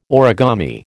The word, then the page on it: origami: American English